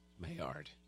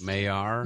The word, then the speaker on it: Maillard: Maillard. – Don't know. – I don't think the D is pronounced. – Maillard. – Maillard. – No, it's... – It's what? – It is what Gary said.